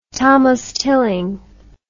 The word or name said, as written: thomas tilling